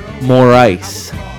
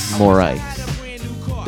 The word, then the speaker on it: Morice: Morice is the pronunciation of the last. Wesley Morice. Just like, I need more ice in this little glass over here.